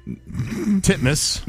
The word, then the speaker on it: Titmus: Pronounce it the way it's spelled. – Ariane Titmouse. Titmus. – There you go. – Is that how you say it? – That is legitimately how you say it.